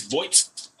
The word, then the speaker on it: Voigts: Am I pronouncing that correctly? – It's Voigts. – Voigts. Voigts. – Voigts, yeah. – Voigts, okay. So there you go.